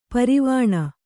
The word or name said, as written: ಪರವಾನೆ